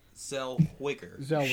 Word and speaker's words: Zellweger: Zellweger.